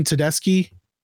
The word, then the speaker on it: Tedeschi: But Susan Tedeschi, is that how you pronounce it? – Yep.